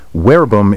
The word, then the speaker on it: verbum: That's because most English words containing V-E-R-B come from the Latin verbum, word. By the way, verbum is spelled V-E-R-B-U-M, but in classical Latin, V is pronounced like W.